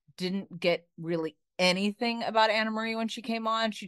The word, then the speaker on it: Annemarie: And she actually had to explain because it's spelled Annemarie. – I know. I know. – So so whatever, Anna Marie.